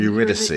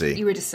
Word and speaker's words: Eurydice: Eurydice. Eurydice.